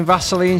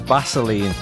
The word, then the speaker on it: Vaseline: Instead of saying Vaseline, she repeatedly pronounced the name as Baseline.